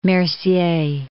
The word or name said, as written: Mercier